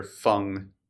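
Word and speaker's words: Feng: And on behalf of a Mr. Fang, I'm never going to say it right. It's F-E-N-G, which is... – Pronouncing it correctly would render Fung.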